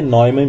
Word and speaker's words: Neumann: Bom, pelo que eu vi na Wikipedia, o nome do John von Neumann se pronuncia von Neumann mesmo. Que falei tudo errado agora. Eu falei que eu tinha corrigido lá que era Neumann, falei Neumann agora. Mas o certo é Neumann mesmo.